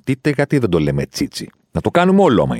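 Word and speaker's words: Tite: Ο Τίτε, γιατί δεν το λένε Τσίτσι, που είναι σωστή προφορά το όνοματό του.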